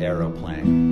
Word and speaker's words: aeroplane: And it's called Aeroplane. We had to use Aeroplane because it has the extra syllable. And, you know, British people call airplanes Aeroplane.